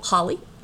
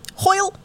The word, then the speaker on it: Hoyle: Her mother, Florence Hoyle? Holly? Not really sure. H-O-I-L-E? – Hoyle. – Hoyle. Florence Hoyle. Let's say it like that.